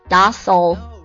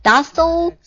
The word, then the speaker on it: docile: 它有两种读音一个是 docile 有点像打扫啊 docile 然后一个是 docile 英式的读法 docile